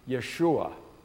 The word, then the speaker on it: Yeshua: The name Jesus is an English transliteration for a Hebrew name, Yeshua. Yeshua means Savior. Yehoshua, anybody named Joshua? Yehoshua, Yahweh is Savior. Yahweh saves. You shall call his name Yeshua, for he will Hoshea his people from their sins.